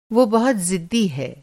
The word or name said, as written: ज़िद्दी